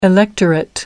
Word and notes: electorate: American English

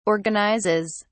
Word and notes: organizes: British English

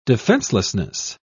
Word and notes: defencelessness: British English

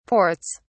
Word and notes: ports: British English

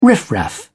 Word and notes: riff raff: British English

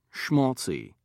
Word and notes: schmaltzy: British English

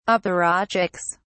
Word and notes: apparatchiks: British English

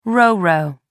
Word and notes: roro: American English

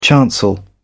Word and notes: chancel: British English

